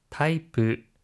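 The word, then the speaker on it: 타이프 (タイプ): 타이프, 타이프, 타이프, 타입, 형태란 뜻인데요. 혹시 발음 딥 있을까요? – 하이, 끝에 있는 리을 받침은 르, 비읍 받침은 프로 발음하는 점 주의해 주세요.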